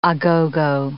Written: a-go-go

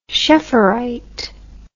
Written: schefferite

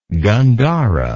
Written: gandhara

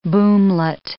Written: boomlet

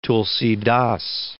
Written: Tulsa

